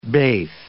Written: beth

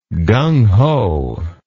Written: gung ho

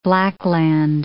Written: blackland

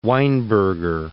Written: Weinberger

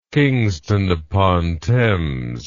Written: Kingston upon Thames

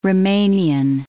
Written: Rumanian

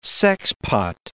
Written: sexpot

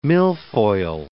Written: milfoil

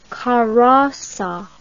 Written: Carossa

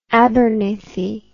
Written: Abernethy

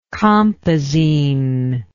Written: compazine

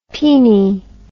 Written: peenie

